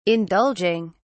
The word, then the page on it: indulging: British English